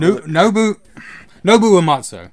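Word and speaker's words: Nobuo Uematsu: The opera scene, again, Umamumutsu. Sorry, that's just offensive. – That is, I felt Nobu Uematsu.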